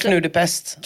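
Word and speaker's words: krijg nou de pest: Och på holländska innan kan man säga, nu får du hjälpa mig med uttalet, krig nu de pest. – Alltså, krig nu de pest.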